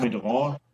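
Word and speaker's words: Commis de Rang: Kommidorant. Äh, Kommidorant. Also das. Comedran, genau.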